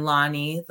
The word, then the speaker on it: Lani: And my name is spelled L-E-I-L-A-N-I. So just think the flower from Hawaii is Lei, and then Lani, the note La.